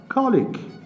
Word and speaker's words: colleague: He is my colleague. دقت بفرمایید کلمه ج اینجا حرفش کا هست، یعنی کا باید تلفظ بشه.